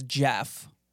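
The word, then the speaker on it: Gef: And it says that its name is Jeff, spelled with a G, G-E-F.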